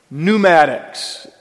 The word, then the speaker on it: Pneumatics: Pneumatics, silent P, has to do with compressed air in accomplishing certain functions.